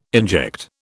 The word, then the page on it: inject: British English